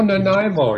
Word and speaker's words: Nanaimo: Ernie Jerome in, I'm going to butcher this name, Nanaimo, British Columbia. – Oh, Nanaimo. Yeah, no, I had relatives who lived there. I know where Nanaimo is.